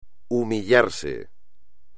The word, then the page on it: humillarse: espagnol